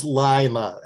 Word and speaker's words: Lima: It is Lima, L-I-M-A, and it stands for Lost in Middle America.